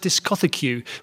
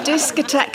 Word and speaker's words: discotheque: In fact, I know a boy who thought the word was pronounced discothecue, which has always stuck in my memory. – Jan. – discotheque and disotheque are actually the same word.